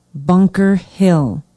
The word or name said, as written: bunker hill